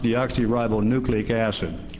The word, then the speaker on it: deoxyribonucleic acid: It's deoxyribonucleic acid, and I've perfected it.